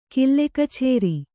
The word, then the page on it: ಕಿಲ್ಲೆಕಛೇರಿ: Kannada